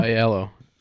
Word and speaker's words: Aiello: Aiello? – Aiello.